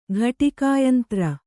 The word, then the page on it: ಘಟಿಕಾಯಂತ್ರ: ghaṭikā yantra